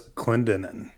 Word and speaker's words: Clendenin: Five men in the nearby town of, how do you say that, Clendenin? Is that right? Clendenin? – That's Clendenin.